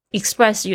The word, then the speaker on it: express: 那 express 有相对的，就是它可能有某些站是不停的。 e x p r e s s，注意一下这个 p 的发音是发成 b，为什么呢？因为它前面加了一个清音是 s 的声音，那所以 p 呢，它就变成浊化，变成 b 的声音。